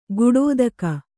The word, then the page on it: ಕಡೆದೆಗೆ: kannada